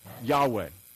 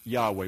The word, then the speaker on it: Yahweh: It's Yod, He, Vav, He. It's Yahweh. We believe that that's pronounced Yahweh. We can't say for certain.